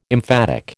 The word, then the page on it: emphatic: American English